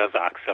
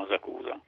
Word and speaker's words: Asakusa: e quindi la pronuncia corretta dovrebbe essere Asakusa, però noi italiani lo chiamiamo Asacusa.